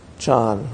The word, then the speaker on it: Chan: So in some ways, this is the first story of Chan, or Zen. Chan's the Chinese way of pronouncing Zen. Japanese say Zen.